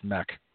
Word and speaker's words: Meek: I believe that's pronounced mech. Not Meek.